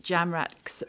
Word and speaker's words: Jamrach: Now, the third one, Jamrach's Menagerie. I know it's pronounced Jamrach because Carol Birch, who's the author, came on this podcast and put me right on air.